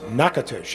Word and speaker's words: Natchitoches: It is spelled very different from the way you pronounce it. Natchitoches. But pronounced Natchitoches.